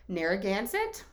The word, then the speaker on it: Narragansett: but pronounce names on YouTube says Narragansett, but the comments don't say that, but that's what I'm calling it.